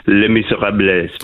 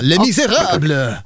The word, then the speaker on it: Les Misérables: Det är ju med lite reservation för min franska uttal. Les Misérables. – Det var fantastiskt uttal. Mycket bra. Les Misérables. – Les Misérables. Alldeles klockrent.